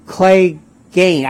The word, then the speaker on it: Clegane: And so Your oh, I have Sandor Clegane. I think the A is long.